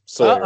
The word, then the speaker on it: Sawyer: It's like Tom Sawyer, so just Sawyer.